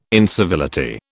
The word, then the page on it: incivility: British English